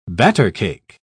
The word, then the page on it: battercake: British English